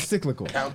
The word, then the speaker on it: cyclical: Says silical. – I think it was cyclical.